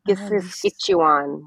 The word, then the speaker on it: kisiskâciwan: I keep trying, and Kisiskatchewan. I don't think I've said that right. – So in Nahiao and Cree, K's are pronounced like G's. So it's Kisiskatchewan, which is kind of like Saskatchewan. Kisiskatchewan.